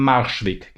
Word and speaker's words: Marschweg: Positiv, ein 0-2-Sieg am Arschweg, manchmal habe ich da irgendwie Aussprechprobleme. – Mensch. – Am Marschweg, genau, so heißt es richtig, genau.